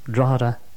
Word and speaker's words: Drogheda: If you wish to write to us, by the way, the address, it's the Free Radio Show, Radio Rainbow International, 151 Moneymore, Drogheda, County Louth, in Ireland. That's the Free Radio Show, Radio Rainbow International, 151 Moneymore, and Moneymore is spelled M-O-N-E-Y-M-O-R-E, Moneymore, Drogheda, D-R-O-G-H-E-D-A, Drogheda, County Louth, County as in C-O-U-N-T-Y, and Louth is spelled L-O-U-T-H, Louth in Ireland.